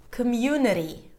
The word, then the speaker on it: community: Community. Nuri. Notice that the ITY suffix will always be pronounced as ri.